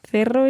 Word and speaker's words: Cerro: Yeah, una montaña, and it's C-E-R-R-O. So in Spain Spanish, we're pronouncing that as Cerro, but if you are more used to Latin American Spanish, then of course you would hear el Cerro de Santa Lucía.